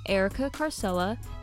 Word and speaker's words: Erika Carsella: If you use my name, Erika Carsella,